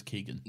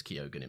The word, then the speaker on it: Keoghan: No, I know, but is it pronounced Keoghan? I thought it was Keegan. – No, I used to think that, but I've seen him in a few interviews where he says Keoghan himself, so...